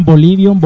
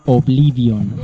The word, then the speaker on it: Oblivion: Bolivion. – Le respondimos. – Bolivion. – El juego no, el juego se llama Oblivion.